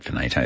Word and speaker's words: Veneto: Yeah, Veneto Club. I said Veneto. How ridiculous.